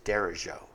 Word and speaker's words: derecho: I would, if I saw that on the menu of a Mexican restaurant, and it's a Spanish word. If I saw that on the menu of a Mexican restaurant, I would pronounce it derecho. Derecho. Like, huevos derecho.